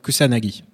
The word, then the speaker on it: Kusanagi: C'est en résumé, en très très résumé, l'histoire dans un futur proche de Major, Motoko Kazunagi, je le dis bien ? Pardon. – Kusanagi. – C'est ce que j'ai écrit en plus. Tu sais que c'est ce que j'ai écrit ? Ouais. J'ai dit Kazunagi, mais c'est Kusanagi qui a surmarqué.